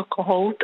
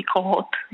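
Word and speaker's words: Kohaut: ale vyslovovalo se to Kohout a nebo i Kohot, jak nám ukazují dobové prameny, kde jeho jméno bylo různě komoleno v matrikách i v dalších dokumentech.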